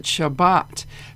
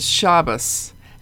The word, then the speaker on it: Shabbat: There are different ways of pronouncing Hebrew words, and modern Hebrew calls it Shabbat, but in the old days we learned it as Shabbos, and I don't know if one is more correct than the other.